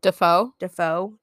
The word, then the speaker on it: DeFeo: And that's when the Defoe, I think is how you pronounce it. – Defoe. How is it spelled? – D-E-F-E-O. – Defoe. I think it's Defoe.